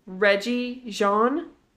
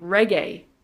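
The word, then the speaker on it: Regé-Jean: It looks like it's Reggie Jean. – Oh, I don't know. Yeah, do we know his last name is Paige, but he actually pronounces his first name Reggae.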